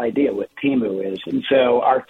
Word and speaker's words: Temu: and it's called Temu, T-E-M-U. Is that how you pronounce it? – I think that's how you pronounce it, yeah.